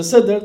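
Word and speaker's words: Sedr (سدر): Om du kollar själva seder, det finns ett seder eller sadr eller sedr, träd på arabiska. Jag vet inte exakt hur den uttalas. Är det sadr eller sedr? Sedr. På persiska man säger konar.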